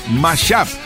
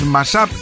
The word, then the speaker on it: mashup: Y realmente, os lo voy a decir en serio, se pronuncia mashup. Ah, ah, y hay gente que nos sigue preguntando, pero es Mashap, es Masap, es Masub, es Mississippi, Mississippi, Massachusetts, Missouri, Massachusetts, los No, no, es Mashap, pero aún así está costando.